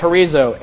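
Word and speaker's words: horizo (ὁρίζω): It's H-O-R-I-Z-O. The Greek word for horizo, the Greek word for declared is horizo, H-O-R-I-Z-O. You pronounce it H-O-R-I-D-Z-O, Z-O, horizo.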